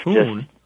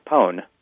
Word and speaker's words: pwn: The word is spelled P-W-N.